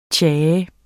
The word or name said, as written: tja